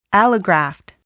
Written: allograft

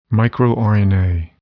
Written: microRNA

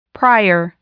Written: prior

